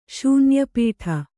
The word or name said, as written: ಶೂನ್ಯಪೀಠ